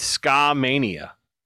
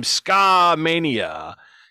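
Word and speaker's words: Skamania: It's Ska-mania. – Ska-mania? – It sounds like a fucking mix record. It sounds like a mixtape. Ladies and gentlemen, producing for the first time, Ska-mania, you know, and it's got the trombone in the back and people are dancing to ska.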